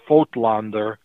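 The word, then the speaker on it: Voigtländer: And there are companies like Voigtlander, pronounced Voigtlander, Zeiss Icon, the camera maker, Carl Zeiss Jena, the lens maker, Certo, C-E-R-T-O, Mentor, all kinds of different companies that made all kinds of different cameras.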